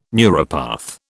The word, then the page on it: neuropath: British English